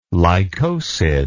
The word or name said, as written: lycosid